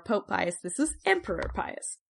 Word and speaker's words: Pius: Some people say Antoninus Pius, but it's spelled the exact same way as Pius, so we're going with Pius today. Saint Justin the Martyr is writing directly to the emperor and directly to the Roman Senate.